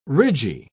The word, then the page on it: ridgy: British English